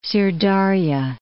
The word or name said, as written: Syr Dar'ya